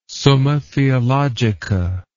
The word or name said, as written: Summa Theologica